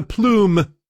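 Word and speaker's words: aplomb: And she is doing it with a plume, which has a silent B.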